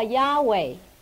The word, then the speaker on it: Yahweh: 那这个名呢，在希伯来原文只是 4个字母 YHWH 是不能发音的，因为全部是子音，所以这犹太人就把它加上了母音 YHWEH，所以叫 Yahweh。